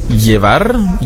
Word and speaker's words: llevar: Wir hatten gestern die Aussprache der Buchstaben Ñe für mañana, das Doppel-L für llevar, wird ausgesprochen wie ein J, das CH, coche, wird ausgesprochen, ja, wie ein TSCH im Deutschen.